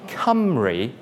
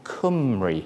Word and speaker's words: Cymru: So it should be Cymru, not as a lot of people think, Cymru. You need a W, which is another Welsh vowel, to get that sound.